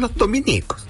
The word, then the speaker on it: Los Dominicos: Si se alude a los religiosos de la Orden de Santo Domingo, se escribe dominico, sin tilde en ninguna i. Por lo tanto, es la estación Los Dominicos, la que queda en Las Condes.